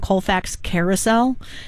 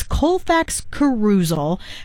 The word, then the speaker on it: Colfax Carousel: Dana's the one who told me about this festival and told me the right way to say it. I've been saying Colfax Carousel. It's Colfax Carousel, happening on September 19th.